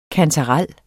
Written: kantarel

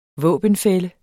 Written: våbenfælle

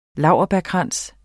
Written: laurbærkrans

